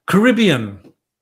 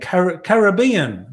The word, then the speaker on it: Caribbean: So if you tell them, okay, you say Caribbean. No, that's the American way of saying it. You've got to say it like an Australian, Caribbean, the Caribbean, right? Say it like that.